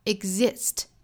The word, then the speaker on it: exist: 那这句话呢，我们要注意的发音点，首先就是 exist 这个单词。那我们要注意这个词，它其实不太好读哈。我们读的时候要注意是 eg，跟后面的 e exist。那当然，我们要注意里面的这个 g 的发音呢，也不要发的过重。